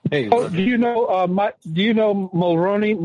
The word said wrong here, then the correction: Maloney: Mike Maloney. – Yeah, I know. I did. I said he. Let me go back in the bathroom. Michael Mulroney. I can't say his name. – Maloney.